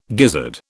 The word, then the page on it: gizzard: British English